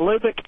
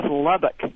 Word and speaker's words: Lubbock: I'm from Texas, and that's not Lubbock, it's Lubbock.